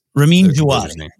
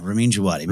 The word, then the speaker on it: Ramin Djawadi: For each film, I think Ramin, what is the proper pronunciation of this? – Ramin Djawadi. Yeah, Ramin Djawadi.